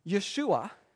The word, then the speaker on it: Yeshua: Even when Joseph is told that Mary is going to have a baby, and the baby should be named Jesus, Yeshua, which is the same exact name as Joshua. We just don't pronounce it right.